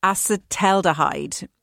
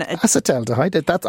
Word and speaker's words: acetaldehyde: Acetaldehyde. And acetaldehyde.